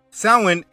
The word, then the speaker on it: Samhain: Now, the word doesn't look how it's pronounced. Samhain is a night celebrating death and hell.